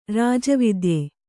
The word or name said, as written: ರಾಜವೈದ್ಯ